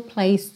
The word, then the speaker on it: place: Place também o som termina nesse s, tá? No C, porque o E não é pronunciado.